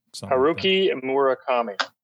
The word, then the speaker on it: Haruki Murakami: I think the one that ultimately was like, was it Haruki Murakami? – Yeah. – Am I saying that right? Haruki Murakami.